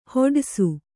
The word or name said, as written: ಹೊಡಯ್ಸು